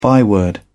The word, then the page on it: byword: British English